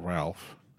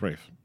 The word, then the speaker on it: Ralph: Ralph Fiennes, not Ralph. It's pronounced Ralph.